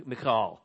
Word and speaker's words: Michal: So not listed here, though, is David's first wife, Michal. M-I-C-H-A-L. It might be pronounced, I guess, in Hebrew, Michal. But anyway, she, will just say Michal.